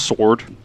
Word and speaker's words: Soard: His last name was S O A R D. It's pronounced Sword.